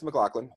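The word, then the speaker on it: MacLachlan: Yeah, it's McLaughlin.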